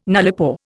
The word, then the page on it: nullipore: Indian